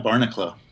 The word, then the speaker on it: Barneklo: Yeah, it's just Brian Barneklo. So, There's a little bit of there's an emphasis at the E.